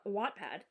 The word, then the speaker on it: Wattpad: Wattpad, because it has 2 T's, so you pronounce it like the watt of a light bulb.